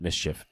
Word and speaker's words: MSCHF: I just recently found out you pronounce it Mischief and not just each letter.